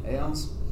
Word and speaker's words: aeons: You pronounced it right. – Yeah, aeons. That's fine.